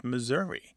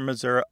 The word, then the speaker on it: Missouri: Because some Missourians don't say Missouri or Missouri, they say Missouri, more like the E in get or met.